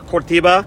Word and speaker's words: Curitiba: Curitiba. – Curitiba. – Curitiba. – Oh, is that how you pronounce it? – Yeah.